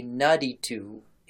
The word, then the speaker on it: Naditu: And so that you can Google this on your own, that is spelled Naditum. But that M on the end is silent because Akkadian apparently is one of those languages that has final consonants that you don't pronounce unless it's like conjugated into something else.